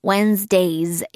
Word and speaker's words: Wednesdays: 그래서 on Wednesdays. 이렇게 z. 발음까지 해주시면 되고요.